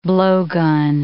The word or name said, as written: blowgun